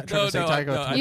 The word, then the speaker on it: Taika Waititi: You're laughing at that, not me saying Taika Waititi.